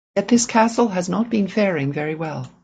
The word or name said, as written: faring